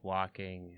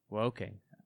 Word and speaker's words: Woking: but I want to play like Walking and, you know, Woking. That's so not woke that I mispronounced it.